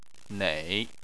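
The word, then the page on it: nei: tone 3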